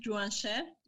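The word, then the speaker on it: Juansher: Can you help me with the pronunciation of his name again? – Yes, Leon Timrovelli and Juanche. Yes, Juanche, that's right.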